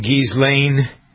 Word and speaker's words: Ghislaine: This is one of your favorite stories, the Ghislaine. I don't pronounce her name right. I never do. Ghislaine? – Mm-hmm. – It's a silent S, right? – Yeah.